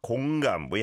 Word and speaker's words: 공감: We have to be a bit careful with the pronunciation because it kind of sounds like 건강, which means health, right? And so 공감, 공감 is today's hashtag. And it's an or vowel sound on 공, 공 like ball, and then 감, 감, 공감. 공감해.